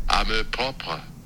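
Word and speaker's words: amour-propre: Amour propre.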